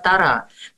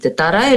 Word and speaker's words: Тора: Вот, кстати, ты еще раз напомни, пожалуйста, вот как правильно произносить, Тора или Тора? – Да и так, хорошо, потому что Тора – это по-русски, а Тора – это да, по-еврицки, да, потому что на иврите слог ударение.